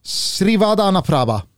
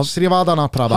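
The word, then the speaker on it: Srivaddhanaprabha: Eh, Leicester-ägaren då, Ayavat Srivad... Nej. Jo, jo, jo, jo. Srivadana Praba. Bra! Srivadana Prabha.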